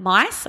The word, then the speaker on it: Myss: I made a choice, and something that I heard in a speech that I was listening to by Caroline Myss, I think that's how you say her last name, but it's spelled M-Y-S-S.